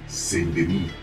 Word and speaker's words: Saint-Denis: Y no solamente París, sino que también está Saint-Denis, como lo pronuncian en el juego. La S al final de algunas palabras en francés es asonante, entonces no se pronuncia.